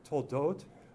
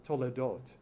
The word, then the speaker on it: Toledot: And so I want to look at some of the big picture tonight, and we'll be looking especially at this uh, some pronounce it, uh, some modern Hebrew speakers, uh, Toldot or uh, Toledot.